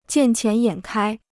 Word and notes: 见钱眼开: jiàn qián yǎn kāi

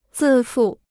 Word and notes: 自负: zì fù

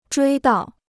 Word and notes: 追悼: zhuī dào

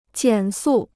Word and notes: 减速: jiǎn sù

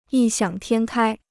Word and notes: 异想天开: yì xiǎng tiān kāi

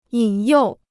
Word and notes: 引诱: yǐn yòu

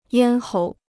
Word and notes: 咽喉: yān hóu

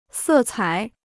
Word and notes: 色彩: sè cǎi

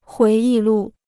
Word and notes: 回忆录: huí yì lù

